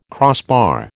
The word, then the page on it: crossbar: American English